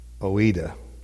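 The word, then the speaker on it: Oida (οἶδα): The first one is, I'm trying to think of pronounce it, O-I-D-A, Oida.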